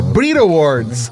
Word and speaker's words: Brit Awards: É Brit Awards. – Entendi. – É Brit Awards, né? Brit Awards. Você come o T na pronúncia em inglês. É Brit Awards.